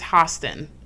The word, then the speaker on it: Hostin: No, actually, I think it's Hostin, how you say it. Yeah, I think it's Sunny Hostin because she said it on the show.